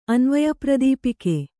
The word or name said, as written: ಅನ್ವಯಪ್ರದೀಪಕ